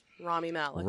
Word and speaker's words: Rami Malek: Can you pronounce it for me? – Well, it's Rami is his first name. – It's Rami Malek, I assume? – Yes, Rami Malek.